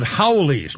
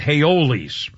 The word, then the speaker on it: haole: called Haoles, not Haoles. H-A-O-L-E.